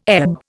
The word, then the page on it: ebb: Indian